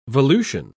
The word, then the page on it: volution: British English